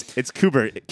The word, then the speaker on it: Qbert: It's Qbert.